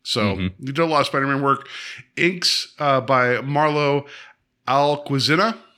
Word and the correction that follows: Alquiza: I'm saying that wrong. – I think it's Alquiza. – Alquiza. Thank you.